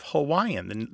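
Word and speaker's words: Hawaii (Hawaiʻi): In the phonetic inventory of Hawaiian, the Hawaiian language, a glottal stop is a part of it. The traditional pronunciation of the state is Hawaii, with a glottal stop in there.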